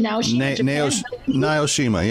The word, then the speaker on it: Naoshima: Naoshima, yeah, you're saying it right.